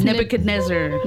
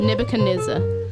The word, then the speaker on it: Nebuchadnezzar: Do you know how to pronounce this? – Yeah, it's Nebuchadnezzar. – Nebuchadnezzar.